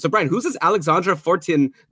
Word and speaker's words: Fortin: Fortin. You're Canadian. You learned French in school. – Oh, it's like Jacques Martin.